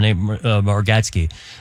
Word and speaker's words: Bargatze: Kevin Carr, by the way, Silver Gecko on Substack, talking about the breadwinner from Bargatze.